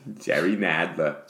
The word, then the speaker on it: Nadler: If you're going to say his name, you have to pronounce it correctly. – Oh, go ahead. – Jerry Nadler. There's no R at the end of that. There's just an A. – Jerry Nadler.